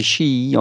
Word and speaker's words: SHEE: Alors, c'est S-H-E-E, e, voilà l'acronyme, on pourrait le prononcer SHI.